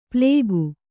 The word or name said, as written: ಪ್ಲೇಗು